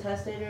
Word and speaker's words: testator: Otherwise, it is of no strength at all while the testator... Is it testator? Am I saying that right? Mm-hmm.